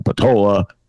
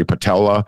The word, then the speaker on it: Peltola: 10 votes for Peltola. That's not how she pronounces her name. – Yeah, Peltola.